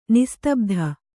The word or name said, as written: ನಿಸ್ತಬ್ಧ